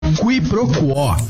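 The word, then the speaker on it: quiproquó: Quiproquo. Q-U-I-P-R-O-Q-U-O com acento agudo. Quiproquo, também informalmente pronunciada como quiproquo, é a ação de confundir uma coisa com outra, ou o desentendimento decorrente dessa confusão.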